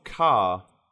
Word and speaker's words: Ka: Yeah, like, or the Ford car. That's the other way of pronouncing it, is the Ford car, which makes it sound like a very generic model.